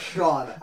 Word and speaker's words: Shjon: That's Sean. – Sean, S-H-J-O-N. – They hate their child, but I guarantee you that's some version of Sean. – I mean, if it was today, I'd believe it. – Sean has more stupid-ass misspellings.